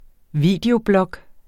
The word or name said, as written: videoblog